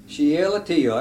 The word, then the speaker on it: Shealtiel: Shealtiel, there you go.